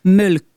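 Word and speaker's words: Mölkky: Ça se prononce Mölk.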